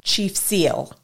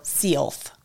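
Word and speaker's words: Si'ahl: Chief of the tribe was Seattle's namesake, Chief Seattle or Chief Seal or Sealth. So we're going to say Seattle because that's where that namesake comes from, but you'll hear it under different pronunciations. Spelt Chief and then S-I apostrophe A-H-L.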